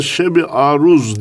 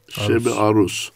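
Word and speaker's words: Şeb-i Arus: Bir kısmı Şeb-i Aruz diye onu telaffuz eder. Yok, aruz vezniyle alakası yok. Sonu S. Şeb-i Aruz.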